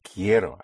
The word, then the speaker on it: quiero: Spelled Q-U-I-E-R-O. The Q-U has the sound of a K. So, quiero, quiero, it's I want. Again, the stress, whenever you have two syllables, and the second syllable starts ends in a vowel, the syllable before last is the first one. This is when you put the stress.